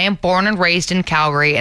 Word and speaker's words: Calgary: A lot of people not from there, or especially in rural places, will pronounce it Calgary, as in Gary the person with Cal also. No, it's actually Calgary. Calgary. You take out that A. No Garys are here.